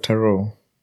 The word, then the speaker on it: tarot: But you've heard of tarot? – What? – I'm assuming that's how it's pronounced. T-A-R-O-T.